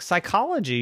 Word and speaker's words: psychology: Well, that one is P-S-T-F-U. I mean, P-S-T-U-F-F-Y. P. Yeah, you know, silent P, like phone. No, you can't have a silent P in front of an S. That makes no sense. And Steffi said, well, sure it does. Like psychology. You know how to spell psychology, right? P-S-Y. So this is P-S-T.